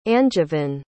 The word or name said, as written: angevin